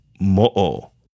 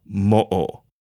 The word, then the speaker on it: mo'o: An okina is pronounced like a pause or musical rest, which changes the pronunciation and may alter the word's meaning. Since the okina is commonly missing from mo'o, this caused various pronunciations of mo'o like mu or mo'o, and makes things tougher for internet searches.